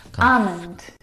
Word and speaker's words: almond: Almond. – Huh? – Now, in this word, the L is silent.